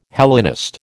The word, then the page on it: Hellenist: American English